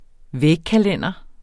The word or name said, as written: vægkalender